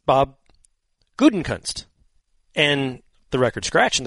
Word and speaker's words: Gutekunst: Brian Gutekunst.